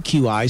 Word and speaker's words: Qi: He had one of those chargers, that Qi, Q-I, spelled pronounced Qi, chargers.